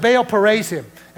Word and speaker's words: Baal Perazim: And David came to Baal, and the town is could be uh, you can pronounce it several ways, Perazim, or I like to call it Perazim because I like to Perazim. Amen. So it's Baal Perazim.